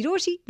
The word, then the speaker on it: Rochie: मेरा नाम है मिर्ची रोशी, नाम की स्पेलिंग है ROCHIE एंड मेरे साथ लाइन पर रितेश है।